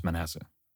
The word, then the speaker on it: Manasseh: Okay, let's face it, I don't know how to pronounce Manasseh. This just in from the internet. The official pronunciation is Manasseh.